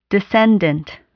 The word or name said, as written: descendant